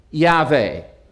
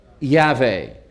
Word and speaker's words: Yahweh: In the Hebrew, phonetically, the abbreviation is something like Yahweh, Yahweh.